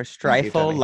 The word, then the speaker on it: Streifel: Just remember, Streifel like rifle.